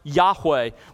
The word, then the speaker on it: Yahweh: Those four Hebrew letters that we pronounce Yahweh.